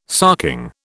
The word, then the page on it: sarking: British English